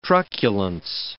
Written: truculence

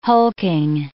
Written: hulking

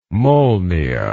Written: Molniya

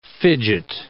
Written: fidget

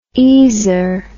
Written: easer

